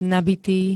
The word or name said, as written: nabitý